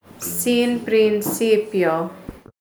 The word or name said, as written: Sin Principio